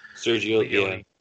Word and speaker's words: Sergio Leone: So Terrence Hill kind of made a name for himself doing spaghetti westerns with different people, but also with Sergio Leone. Is that how you pronounce his name? – Sergio Leone. – Yeah.